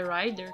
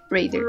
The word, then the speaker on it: Raider: É Rider que fala? – Raider. Raider.